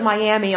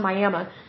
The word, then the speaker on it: Miami: We don't pronounce it Miami, unfortunately. It's pronounced Miami.